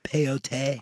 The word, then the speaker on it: peyote: I've done peyote one time at a powwow that It's pronounced peyote.